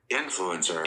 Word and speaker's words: influencer: Influencer. – Influencer. The accent is on in.